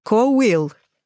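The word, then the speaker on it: bhfuil: Кобфил. – Вот. Я бы тоже сказала, что это какой-то кобфил, а читается коуил.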